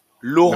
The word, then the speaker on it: Laurent: Euh, Valentin, alors, pardon, Laurent, Laurent Pignon,